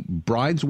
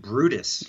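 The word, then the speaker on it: Bryde's: Am I correct that these whales were at one time believed to be bride's whales? – Yeah, interesting. That's kind of how it's spelled. It's actually pronounced Brutus whales, which are found around the world.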